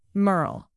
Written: merle

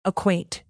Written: acquaint

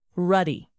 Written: ruddy

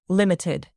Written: limited

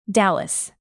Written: dallas

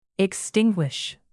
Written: extinguish

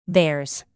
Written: theirs

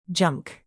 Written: junk

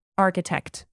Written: architect

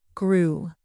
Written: grew

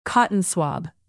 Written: cotton swab

